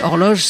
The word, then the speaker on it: Orloj: On écoutait Horloge, c'était le Liar Optimix, et Horloge s'écrit O-R-L-O-J.